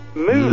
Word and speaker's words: Moulay: Mule Idris. – Mule Idris. – Mule? – Mm. Yeah, that's spelled M-O-U-L-A-Y. – Mule. – Mulevu by ABBA? – No, M-O-U-L-A-Y.